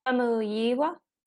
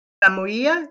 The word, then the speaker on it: Famuyiwa: Famuyiwa. Famuyiwa, yeah, yeah.